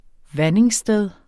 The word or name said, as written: vandingssted